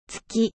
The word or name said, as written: lune